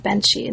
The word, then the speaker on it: Benshi: So the first part of the book looks at early Japanese film stars, and it focuses mainly on Benshi. Am I pronouncing that correctly? – Yes. – Benshi, great. Benshi, and on a particular figure, a particular Japanese film actor as well.